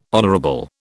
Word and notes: honourable: British English

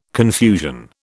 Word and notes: confusion: British English

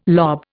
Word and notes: lob: Indian

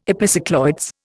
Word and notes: epicycloids: Indian

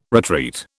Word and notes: retreat: British English

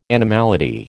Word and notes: animality: American English

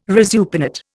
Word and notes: resupinate: Indian

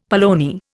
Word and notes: polony: Indian

